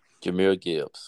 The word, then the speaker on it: Jahmyr Gibbs: Jahmyr Gibbs.